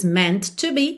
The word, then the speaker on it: meant to: E meant to, como eu até já falei hoje lá na parte 2, é, você pronuncia um T só. Então, não diz meant to be, e sim meant to be, ok?